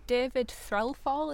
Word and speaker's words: Threlfall: David Threlfall, is that how it's pronounced? – Mm-hmm.